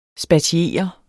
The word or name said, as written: SPATIERET